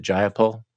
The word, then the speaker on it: Jayapal: Is it Jayapal? – Yes.